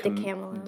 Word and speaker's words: DiCamillo: Is that how you pronounce her name? – It's that's how I pronounce DiCamillo. – It. I pronounce it DiCamillo.